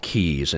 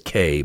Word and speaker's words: Caye: And, again, all the islands are called Keys, and it's spelled C-A-Y-E. And everybody says K, but it's actually Key, like the Florida Keys.